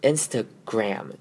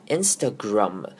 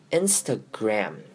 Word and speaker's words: Instagram: 接下来我们来看今天最后一个重点，也是最多人会犯的错呢，就是最后一个字 Instagram。 Instagram，这个就是台湾人所常用的 IG，那我们这标准的念法呢是 Instagram，注意的是后面的 GRAM M 的声音呢，不可以念成不可以念成 Instagram，要念成 A 短母音 A Instagram，这样子才对。